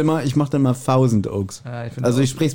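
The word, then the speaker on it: Thousand Oaks: 1000 Orks.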